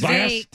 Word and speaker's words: Vivek: Oh, it's Vivek. – Deborah Vivek. Mark. – Vivek. Vivek. – Yeah, it's like Vivek. – Cake. – Vivek. – It's like cake, Vivek.